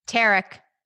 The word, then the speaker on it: Tarek: We have a flip or flop star, Tariq Tarek. – Tarek. Say it's spelled like Tariq.